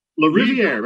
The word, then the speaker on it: Lariviere: And author David, help me with the pronunciation so I don't hack this too bad. Lariviere, I'll go with. – Lariviere, very close.